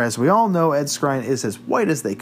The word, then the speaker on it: Skrein: We had a debate off camera of how to pronounce this. We're going to go Skrein. He steps down from the Hellboy reboot film over concerns of potential whitewashing.